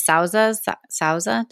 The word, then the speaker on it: Sauza: So the other family that produced the liquor was the, oh gosh, how do you say it? Sauza? Did I say that right? S-A-U-Z-A. It's like the second biggest brand, but I don't actually know how to pronounce it.